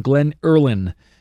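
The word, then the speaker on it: Glen Earlyn: It's G-L-E-N and then Early, E-A-R-L-Y, the letter N at the end. Glen Early and then N, Glen Irland is how it's pronounced.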